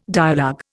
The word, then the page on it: dialogue: Indian